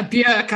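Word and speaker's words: Björk: So probably a lot of that, but also I'm going to butcher her name and I'm so sorry, but Bjork? – Oh yeah, Bjork, I think is a yes.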